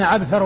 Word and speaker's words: عبثر: وعبثر هذا هو الذي مر بنا في المصطلح بين عبثر وعنبر وأنهما من قبيل المؤتلف والمختلف، يعني يتفقان في الرسم ويختلفان في النطق، عبثر وعنبر. فعبثر بن القاسم هو بالعين والباء والثاء والراء،